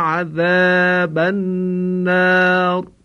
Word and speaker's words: عذاب: این رو هم باید مراقب بود که مانند حرف زای فارسی تلفظ نشه، یعنی بخوانیم و قنا عذاب النار.